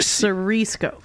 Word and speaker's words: Siriesco: You were close. Siriesco.